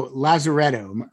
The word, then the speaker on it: Lazaretto: So Lazaretto, are we saying that right? – Yeah.